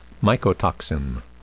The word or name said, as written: mycotoxin